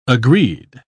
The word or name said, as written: agreed